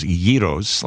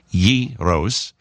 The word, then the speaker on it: gyros: You know, the gyros is what a lot of people call it, but the correct way to pronounce it is gyros, like G-H-E-E, gyros.